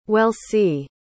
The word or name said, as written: welsie